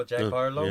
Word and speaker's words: Harlow: And that's why I respect, you know, Jack Harara. I'm probably saying his name wrong. That kid who came out with the time was popping. – Oh, Jack Harlow? – Yeah.